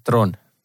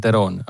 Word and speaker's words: Theron: täpselt nii ja tegelikult tema nime hääldatakse Charlize Tron. Tron. Tron. Game of Throne. Game of Throne, jah, põhimõtteliselt Charlize Tron, mitte Teron, aga kogu maailm teab teda ikkagi Charlize Teronina.